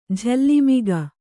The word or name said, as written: ಜೋಲುಮುಖ